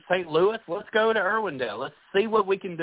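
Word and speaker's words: Irwindale: Irvindale is the name of the racetrack there in Bakersfield.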